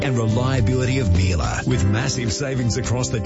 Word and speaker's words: Miele: I've heard from the Miele's themselves. In Australia, Miele rhymes with Sheila.